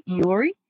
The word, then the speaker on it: Iori: Iori, and it's really hard to pronounce.